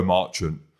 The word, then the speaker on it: Marchant: No, in your French it is Marchand, but in English it's Joe Marchant. – Marchant, okay, yeah, Marchant, like on the line just behind me.